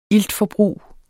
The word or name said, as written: iltforbrug